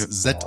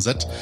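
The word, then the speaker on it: sí: Auch das mit den Set das ist also die Feenhügel ähm, nennt man in dem äh, Gälischen, glaube ich, heißt es Set ausgesprochen. – Hm. – Wird Sit geschrieben mit so einem kleinen Ding drüber, ausgesprochen Set.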